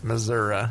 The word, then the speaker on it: Missouri: Learn how to say it right, though. It's Missouri.